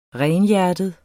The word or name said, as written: renhjertet